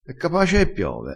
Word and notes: e capace: dialettale

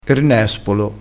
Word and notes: nespolo: dialettale

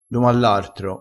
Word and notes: domallartro: dialettale

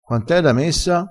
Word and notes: messa: dialettale

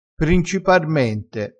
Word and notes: principarmente: dialettale

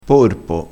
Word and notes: porpo: dialettale

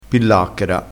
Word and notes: pillacchera: dialettale

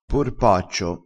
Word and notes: porpaccio: dialettale

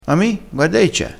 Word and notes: mi: dialettale

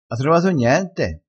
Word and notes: cosellina: dialettale

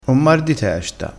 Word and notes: mar: dialettale

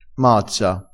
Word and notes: mazza: dialettale

